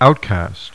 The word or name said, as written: outcast